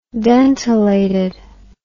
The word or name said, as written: dentelated